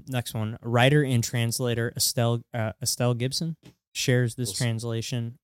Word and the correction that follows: Gilson: What did I say that wrong? – Gilson.